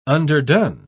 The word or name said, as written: underdone